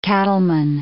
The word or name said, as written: cattleman